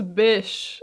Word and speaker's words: Bysshe: So let's start with Harriet Westbrook and Percy Shelley, whose middle name is conveniently pronounced as Bysshe.